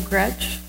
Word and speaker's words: Gretsch: And Joel Gretsch, did I say that right? – Yes.